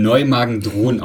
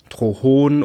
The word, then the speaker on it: Neumagen-Dhron: Leute sagen, also das Neumagen sagen Leute meistens richtig, aber Dhron wird manchmal mit T oder das H an einer anderen Stelle ausgesprochen, dann ist es so Neumagen. Drohon oder sowas, das ist ganz lustig, genau.